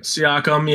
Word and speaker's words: Siakam: Siakam? I'm not sure if I said that right. – Siakam, yeah.